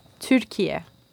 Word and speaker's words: Türkiye: I think I asked this last time, but how do you say turkey in Turkey again? – Türkiye.